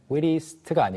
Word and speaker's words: wittiest: 그리고 재치 있는 거 witty, 언변 좋은 거 eloquent 설명드렸는데 발음 하나만 보자면 witty 같은 경우에는 witty로 원래는 끝났잖아요. 근데 뒤에 이제 est를 붙이면 wittiest가 아니고 wittiest. – 어가 살짝 들어가요.